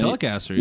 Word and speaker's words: Telecaster: Is it Telecaster? Did I say that right? – Telecaster, yeah.